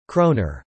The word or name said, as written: kroner